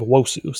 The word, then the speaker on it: Nwosu: Silent N. Gabe Wosu. Silent N there.